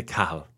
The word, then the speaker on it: Cahill: In Ireland, we say Cahill.